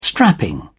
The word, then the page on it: strapping: British English